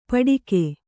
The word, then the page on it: ಪಟಿಕೆ: paṭike